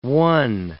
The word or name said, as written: one